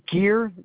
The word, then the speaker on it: Geer: All right, David, David, Gear, GEER.